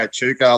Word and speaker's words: Echuca: sorry, yeah, Chuco. That's how you say it. – Yeah, yeah, okay. That's right. Yeah, yeah, yeah. Oh, most people know it from like I, I usually just say Echuca, like most people know it from the Southern eighty.